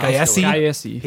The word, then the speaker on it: Gyasi: And then there's a character named Romilly, played by, how do you say his name, David Gyasi? – Yeah. – Gyasi.